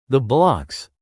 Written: the blocs

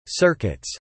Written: circuits

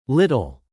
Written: little

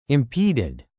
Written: impeded

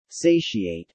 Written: satiate

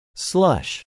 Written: slush

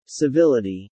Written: civility

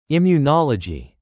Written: immunology

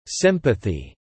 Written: sympathy

